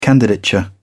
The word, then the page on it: candidature: British English